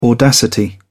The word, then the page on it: audacity: British English